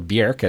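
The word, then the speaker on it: Björk: Bjork has a role here as well, or Bjork, as I think is properly pronounced from the from Iceland.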